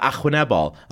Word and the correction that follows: arwynebol: I went, no, Arwynebol.